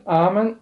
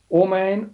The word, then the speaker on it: Amen: In het Hebreeuws is de uitspraak Amen, Amen, Omein of Omein. In het Aramees wordt het uitgesproken als Amin. In het Arabisch, het Nieuws Grieks en het Russisch is het ook Amin. Of Amen.